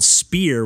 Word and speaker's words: Speer: There was actually, I had a recent back and forth with the developer on Twitter of this game called Spear, which is spelled S-P-E-E-R, and it actually has like brackets around it.